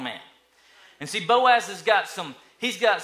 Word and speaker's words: Boaz: Everybody say Boaz. It's spelled B-O-A-Z.